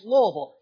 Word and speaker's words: Louisville: It is Louisville. Say it as fast as you can. Try to say it with one syllable if possible.